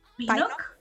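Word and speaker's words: Pinnock: Yo no sé cómo pronunciar su apellido. Lian Pinoc. – Pinock, sí, Pinock. bueno, no, sí.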